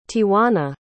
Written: tiauna

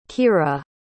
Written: kiera

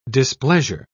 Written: displeasure

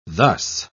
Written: thus

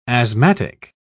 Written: asthmatic

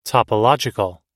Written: topological

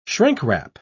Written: shrink-wrap

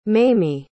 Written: mamie

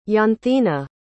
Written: janthina

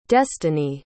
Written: destini

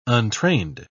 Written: untrained

